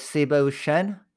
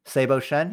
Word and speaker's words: Seibo Shen: My name is Seibo Shen. Seibo Shen.